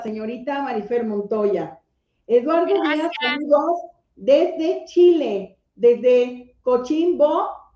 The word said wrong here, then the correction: Coquimbo: es Coquimbo, Chile, Cochimbo, Chile.